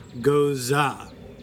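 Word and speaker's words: Gose: It's apparently, according to the pronunciation stressed in his comment and response, Goza.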